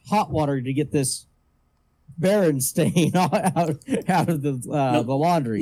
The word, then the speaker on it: Berenstain: Berenstain.